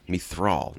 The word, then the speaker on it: Mithral: He just kind of named the Mithril, right? I always say that wrong. – Mithral, not Mithril.